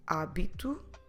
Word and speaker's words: hábito: In Portuguese, the H is completely silent. So we did already hábito, helicóptero, hipermercado, a hypermarket, hipermercado, hora, hour.